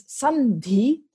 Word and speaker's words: sandhi: And um, sam D becomes Sandhi because an N is more e- is easier to pronounce in front of the D. D. They're both pronounced with your tongue against your teeth.